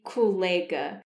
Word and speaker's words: colega: Então, é muito comum ouvir colega, uma mistura entre colleague e colega. Mas a pronúncia correta é colega.